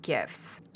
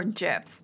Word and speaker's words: GIF: ね、 I think more popular here are GIFs or GIFs, depending on who you're talking to. – Oh, GIFs or GIFs. Oh. – Yeah. – Okay, okay. – There's a debate on like whether it's a hard G or a soft G.